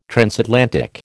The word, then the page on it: transatlantic: American English